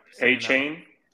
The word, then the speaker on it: Achane: It might not be that deep, but I'm picking Devin Achane. – A-Chain. – I don't think that's how you say it. But, all right, Devin A-Chain.